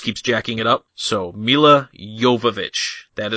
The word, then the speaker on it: Jovovich: And for those of you listening at home, that is the correct way to say her name. Milla Jovovich. I heard her say it. I listened to the director's commentary on this and she was on it.